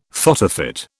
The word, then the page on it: photofit: British English